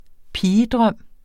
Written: pigedrøm

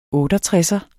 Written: Er

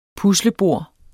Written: puslebord

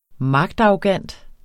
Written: magtarrogant

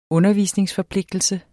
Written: undervisningsforpligtelse

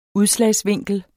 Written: udslag

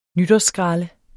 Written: nytårsskralde

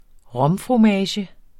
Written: romfromage

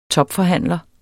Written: topforhandler

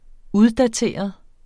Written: Uddateret

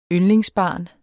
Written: yndlingsbarn

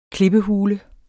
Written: klippehule

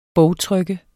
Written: bogtryk

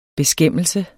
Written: beskæmmelse

